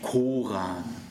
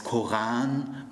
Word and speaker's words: Koran: Ihr sagt ja auch nicht Koran. Ihr sagt ja auch nicht Moschee. Das heißt Koran, Moschee. Die arabischen, semitischen Worte werden alle auf der zweiten Silbe betont.